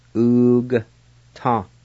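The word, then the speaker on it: ugata: And the A with the G is silent in this word. You just pronounce the G like this. Oog ta.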